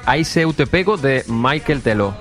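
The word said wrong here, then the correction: Michel Teló: Michel Telo.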